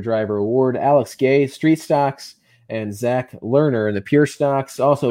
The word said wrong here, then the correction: Legner: Legner. Zach Legner. – Legner. – You got to work on your pronunciation tonight.